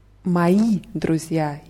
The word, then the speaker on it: мои: Ja, мои друзья. Hier ist die Betonung auf i, weil moi heißt ähm, mein, aber mm, wenn, wenn jemand männlich ist, äh, moi Druck würde moi mhm.